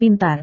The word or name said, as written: pintar